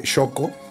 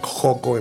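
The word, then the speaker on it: Xoco: Y después, bueno, pues este, ir al hospital de Shoko o, o como lo pronuncian otras personas. Otras pronuncian, pronuncian Joco en vez de Shoko.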